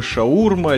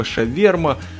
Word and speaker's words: шаурма: Есть такое замечательное блюдо, называется шварма. Но как-то между Питером и Москвой произношение этого слова различается, то есть это либо шаурма, либо шаверма.